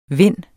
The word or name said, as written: vind